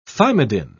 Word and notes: thymidine: British English

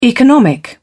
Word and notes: economic: British English

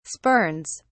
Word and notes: spurns: British English

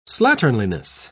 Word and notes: slatternliness: British English

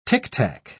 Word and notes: ticktack: British English

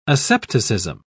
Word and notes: asepticism: British English

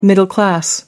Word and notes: middle-class: American English

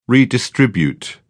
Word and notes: redistribute: British English

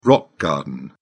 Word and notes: rock garden: British English

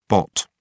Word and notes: bot: British English

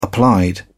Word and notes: applied: British English

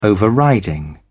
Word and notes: overriding: British English

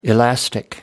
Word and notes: elastic: American English

